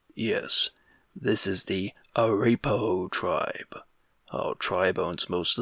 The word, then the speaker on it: Arapaho: You mispronounce Arapaho. – Yes, this is the Araparo tribe. – Arapaho!